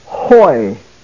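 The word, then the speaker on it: hoi (οἱ): It is pronounced hoi.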